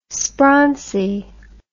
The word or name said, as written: sprauncy